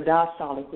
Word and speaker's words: diastolic: It has the systolic, which is the top number, and the diastolic, which is the lower number, the bottom number.